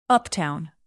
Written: uptown

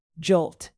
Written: jolt

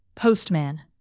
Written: postman

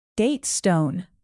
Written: datestone

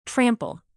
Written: trample

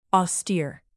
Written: austere

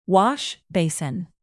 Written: washbasin

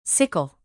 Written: sickle

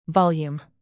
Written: volume

